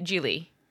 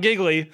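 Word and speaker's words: Gigli: I think that's how you say it, Geely. – No, I think that's how you say it. – Yeah. – It's not giggly.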